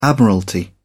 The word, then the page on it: admiralty: British English